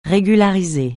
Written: régulariser

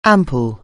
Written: ampoule

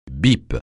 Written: bip

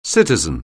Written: citizen

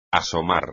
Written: asomar

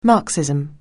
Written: Marxism

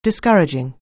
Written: discouraging

